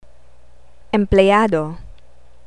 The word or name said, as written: empleyado